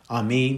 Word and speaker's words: Ami: Ami means my people. Am means people, and the ending with the I, pronounced as a double E, Ami, means my.